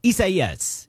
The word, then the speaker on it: Isaias: Hurricane Isaias, I think, is how you properly pronounce that. Let's check with Metro News meteorologist. Am I saying it right? Close enough? – Yes. Yeah, close enough. Isaias.